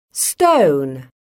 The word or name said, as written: stone